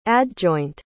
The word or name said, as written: adjoint